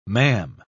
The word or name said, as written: ma'am